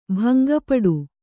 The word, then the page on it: ಭಂಗಪಡು: bhanga paḍu